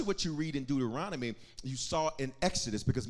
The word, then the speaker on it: Deuteronomy: I want everybody to say the word Deuteronomy with me, because sometimes when I hear Christians pronounce Deuteronomy, I hear things that are not Deuteronomy. Pepperoni pizza, Duma Roma, Giordano's. This is not a pizza.